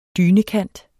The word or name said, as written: dynekant